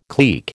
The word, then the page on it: clique: American English